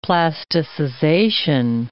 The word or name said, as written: plasticization